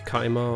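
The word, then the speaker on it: Khaima: Es kommt aus dem Arabischen und ich hoffe, ich liege nicht komplett falsch, wenn ich es als Kaima ausspreche. In unserem Gebrauch, im englischen Gebrauch, wird es als Kaima ausgesprochen.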